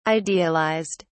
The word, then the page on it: idealised: British English